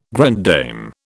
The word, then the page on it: grande dame: British English